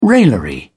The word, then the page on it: raillery: British English